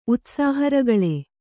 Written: ಉತ್ಸಾಹರಗಳೆ

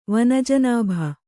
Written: ವನಜನಾಭ

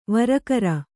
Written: ವರಕರ

